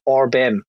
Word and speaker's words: Orbem: Is that how you pronounce this? Orbem. Uh, yeah, that's it.